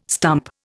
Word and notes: stump: Indian

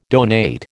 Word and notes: donate: American English

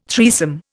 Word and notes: threesome: Indian

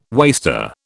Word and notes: waster: British English

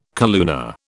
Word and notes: calluna: British English

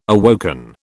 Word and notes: awoken: British English

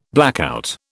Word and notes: blackout: British English

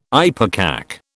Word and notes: ipecac: British English

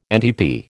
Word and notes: NTP: American English

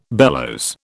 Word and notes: bellows: British English

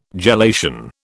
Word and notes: gelation: British English

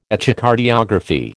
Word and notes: Echocardiography: American English

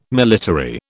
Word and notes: military: British English

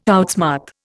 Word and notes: outsmart: Indian

